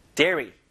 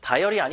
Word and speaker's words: dairy: dairy. 그죠? 다이어리 아니에요, 여러분. dairy products. dairy products. 요거 발음 잘해야 돼요.